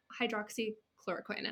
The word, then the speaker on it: hydroxychloroquine: So So Amy has, you've been on hydroxychloroquine, and I hope I'm saying that right.